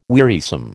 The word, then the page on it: wearisome: American English